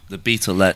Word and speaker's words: The Beatles: The Beatles.